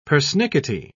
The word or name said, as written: persnickety